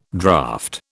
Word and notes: draft: British English

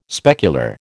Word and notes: specular: American English

